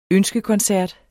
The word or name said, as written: ønskekoncert